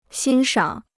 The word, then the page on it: 欣赏: xīn shǎng